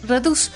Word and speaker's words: Redux: Es Redux con X, pero yo no lo puedo pronunciar con X, me suena raro.